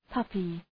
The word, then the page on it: puppy: ‘pʌpı